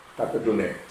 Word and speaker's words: Tatadune: it's spelled D-U-N-E, and I said it's Tatadun, and that's wrong. It's Tatadune.